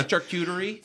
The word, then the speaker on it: charcuterie: Well, thank God it wasn't called a charcuterie board. Am I saying that right? – Yeah, charcuterie.